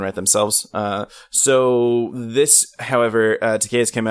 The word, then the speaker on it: Takei: Takei. George Takei. – It rhymes with gay. That's how you remember it.